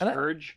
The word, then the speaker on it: Hergé: It's pronounced Hergé.